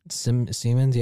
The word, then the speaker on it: Siemens: The electronics company Siemens, right? – Siemens, yeah.